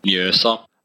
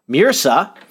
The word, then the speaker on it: Mjøsa: Mjøsa. – Mjøsa.